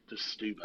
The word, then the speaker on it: Setúbal: But I said, actually, in Portugal, O's are pronounced like U's. So Boal is pronounced Buau anyway. So it's not Moscatel de Setúbal, it's Muscatel Stubal.